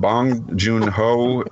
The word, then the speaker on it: Bong Joon-ho: Okay, and Bong Joon-ho is the director. – Absolutely, dead on, man.